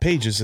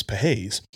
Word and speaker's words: Pages: You will be shocked to know that I mispronounced the guy's name, but it's not Pages, it's Pahez.